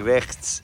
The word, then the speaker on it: rechts: Oh man, you don't understand how long it took me to say rechts. I can't even say it properly. Rechts, rechts. Oh man, it's the hardest sound ever to say. G and a H and a S together.